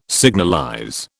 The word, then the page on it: signalize: British English